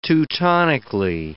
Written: Teutonically

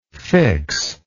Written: fixability

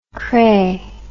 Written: cray

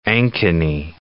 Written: Ankeny

